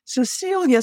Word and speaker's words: Cecelia: So it's Cecilia spelled with two E's. This came from my grandmother. Oh, Lordy. C-E-C-E-L-I-A.